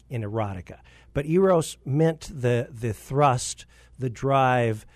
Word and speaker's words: Eros: The Eros, E-R-O-S. Pronounce it either Eros as in erotic or Eros.